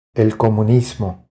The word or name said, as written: El comunismo